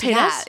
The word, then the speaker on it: Paytas: Paytas? – Yeah, Trisha Paytas.